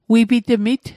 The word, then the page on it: wipitmit: Mi'gmaq